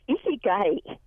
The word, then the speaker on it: Ikigai: I believe it's pronounced Ikigai, I-K-I-G-A-I, The Japanese Secret to a Long and Happy Life.